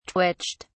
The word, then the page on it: twitched: British English